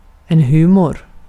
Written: humor

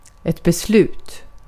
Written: beslut

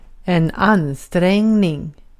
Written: ansträngning

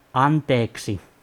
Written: anteeksi

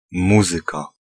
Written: music

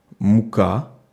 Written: pain

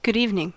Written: good evening

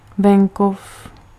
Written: campagna

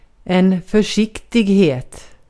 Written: försiktighet

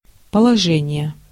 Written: quality

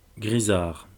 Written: grisard

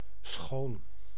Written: beautiful

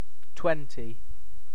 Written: twenty

